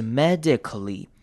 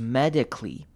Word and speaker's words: medically: Medically 这个字也要特别注意的是，后面 a 的声音呢，我们可以念成轻音，也就是念成的声音，或者是呢，很多人念的时候也会把这个声音直接省略掉，所以我们可以念成，如果是轻音的话，就是 medically，那如果省略掉的话，就是 medically，在日常生活的话，两种都会听到。